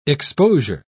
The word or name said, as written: exposure